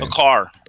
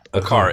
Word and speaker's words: Aquart: Um, dude, how do I say his name? I can't remember his name. A car. A car. A Q U A R T.